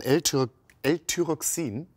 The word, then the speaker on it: L-Thyroxin: Wir haben es gerade im Beitrag gesehen, Sie haben es gerade nochmal angesprochen, L-Thyroxin, wird das so ausgesprochen? – Genau.